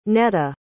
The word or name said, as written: netta